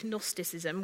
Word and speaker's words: Gnosticism: Gnosis is to know in Greek, Gnosticism, with a silent G.